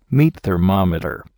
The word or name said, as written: meat thermometer